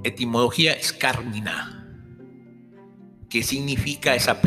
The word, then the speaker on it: Carmina: Aunque generalmente aparece escrito Carmina sin tilde como en el original latín, su pronunciación correcta y reincido nuevo es Carmina.